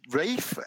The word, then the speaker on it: Ralph: It is Rafe, is how it's spelled, R-A-L-P-H, Rafe Fiennes.